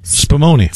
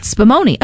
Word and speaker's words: Spumoni: You're going to have to help me out with this one. True Italian Spumoni. – Spumoni. Okay, so I did say it right.